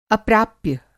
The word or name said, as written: अप्राप्य